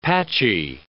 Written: patchy

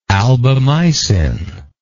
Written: albamycin

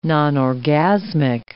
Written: nonorgasmic

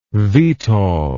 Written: vtol

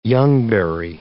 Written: youngberry

